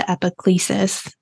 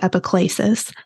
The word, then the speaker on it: epiclesis: That is part of the epiclesis, or some people pronounce it epiclesis, for Eucharistic Part A.